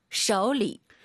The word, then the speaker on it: 手里: 手里。 – 왜냐면 이 리가 삼성이에요, 원래. 그래서 삼성, 삼성이니까 앞에가 이성, 그다음 반삼성. 니하오, 쇼리. 이런 발음이 나온 거예요.